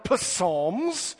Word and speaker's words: Psalms: Now, some people might call it Psalms because it starts with a P, but here the P is silent, which is how it always should be.